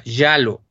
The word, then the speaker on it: Giallo: Hay, una cosa importante, es un género que en italiano se llama, a ver si lo pronuncio bien, hasta estuve buscando la pronunciación exacta, Yalo. Se escribe G-I-A-L-L-O y quiere decir amarillo.